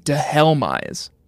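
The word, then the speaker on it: Dhelmise: They say here that it's a combination of demise and helm, which I dehelmize is, I think, the way Bulbapedia wants us to pronounce it. So I guess we'll go with the official pronunciation. Since we get so much shit about how we pronounce Pokemon names, we'll go with the official Bulbapedia pronunciation, dehelmize.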